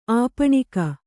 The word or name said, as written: ಆಪಣಿಕ